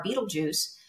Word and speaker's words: Betelgeuse: And there's some scientists that recently decided to point the Hubble telescope at Orion's Belt and they were looking at the star Betelgeuse, which is a very, very old star. I know it sounds like Michael Keaton Betelgeuse, but it's spelled totally differently.